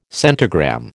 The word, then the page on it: centigram: American English